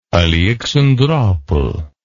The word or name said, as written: aleksandropol